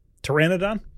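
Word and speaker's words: Pteranodon: Tyrannodon? – Tyrannodon? – Yep. – Oh, you don't pronounce the P with dinosaurs. My Latin is rusty.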